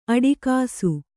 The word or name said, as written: ಅಟಕಿಸು